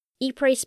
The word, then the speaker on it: Ypres: Ypres, spelt Y-P-R-E-S, with a silent S. Took me a long while to work out how to pronounce it.